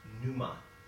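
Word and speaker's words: pneuma (πνεῦμα): The Greek word used for spirit here is a word pronounced pneuma. Pneuma.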